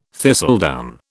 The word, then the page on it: thistledown: British English